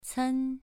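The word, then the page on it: cen: tone 2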